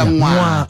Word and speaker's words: Mwamba: And by the way, I always say to people, you pronounce my surname as Mwamba. You're like a Mwamba.